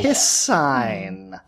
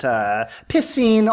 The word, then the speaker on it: piscine: Pissing also is an acceptable pronunciation, but I want to keep them all predictable here.